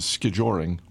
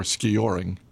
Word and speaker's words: skijoring: My personal favorite was skijoring or skioring, depending on how you pronounce it, which was back in the 1920s.